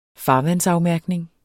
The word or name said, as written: farvandsafmærkning